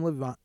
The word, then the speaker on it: Levine: Will Adam Levine.